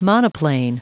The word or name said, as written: monoplane